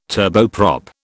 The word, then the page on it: turboprop: British English